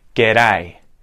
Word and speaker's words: G'day: It's the second syllable that's getting really emphasized. G'day, g'day, d-dun, d-dun, g'day, g'day.